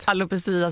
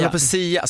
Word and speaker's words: alopecia: Men hur säger man det rätt? – Alopecia säger jag. Alopecia, så jag är på engelska. – Ja, yeah. Alopecia kan man ju säga.